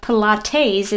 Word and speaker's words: Pilates: P-I-L-A-T-E-S. – Okay. Pilates. – Pilates, yes. Correct. Yeah. He calls it Pilates. Like T-A-Z-E. – This sounds like an insurance situation. – Yes. Insurance. – Or Five Guys. – Five Guys. Well, no, because that's... He's putting... Insurance and Five Guys is emphasis on the first syllable.